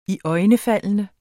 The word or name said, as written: iøjnefaldende